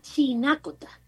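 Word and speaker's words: Chinácota: Bueno, mira, se llama Chinacota. Con la tilde en la...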